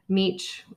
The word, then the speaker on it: Mech: And the thing is alpha, the David, I believe his last name is pronounced Meech, but it's spelled M-E-C-H, and you can look it up.